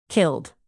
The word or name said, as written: killed